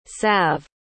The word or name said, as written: salve